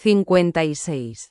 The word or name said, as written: 56